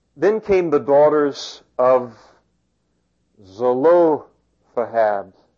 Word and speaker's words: Zelophehad: That's the correct pronunciation. I'm going to go ahead and repeat that for you because I took the time to look this one up. Zelo. Lo is in all caps because that's what you want to pronounce the strongest there. I know there's a correct word for that, but it's escaped me. So it's Zelophehad,